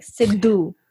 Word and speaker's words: Sidhu: It's like Sidhu, like a duh, not ta, not duh, duh.